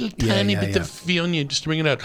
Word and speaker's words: Viognier: Viognier.